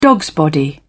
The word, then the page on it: dogsbody: British English